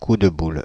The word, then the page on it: coup de boule: [ku də bul]; /ku də bul/; /ku‿d bul/